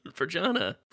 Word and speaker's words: Vergana: Vergana. – Vergana. Okay. Hard G.